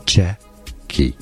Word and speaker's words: Czechia: Czechia. Czechia. Stress on the first syllable.